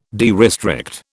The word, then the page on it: derestrict: British English